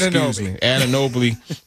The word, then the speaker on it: Anunoby: You got the defensive specialist and OG Anunoby. I can never pronounce his name. – Anunoby. – I was going to put that L in there for some reason.